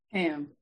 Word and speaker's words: Kham: K-H-A-M, say your name for me, dear. – Cam.